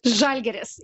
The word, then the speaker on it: Žalgiris: And did I pronounce it well? Žalgiris, yeah, correct.